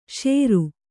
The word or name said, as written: ಸೌರ